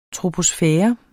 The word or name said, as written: troposfære